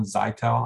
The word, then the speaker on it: Zytel: Yeah, it's nylon, I think it's called nylon Zytel.